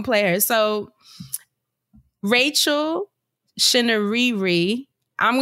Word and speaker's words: Chinouriri: So, Chinouriri. Shinariri. And I looked her up on YouTube, found an interview because I wanted to make sure I said it right. So, Rachel Shinariri.